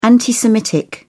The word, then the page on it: anti-semitic: British English